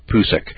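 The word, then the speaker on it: Pucek: Well, back in 1991, a fellow by the name of Ron Pusek, P-U-C-E-K, I'm going to pronounce it Pusek,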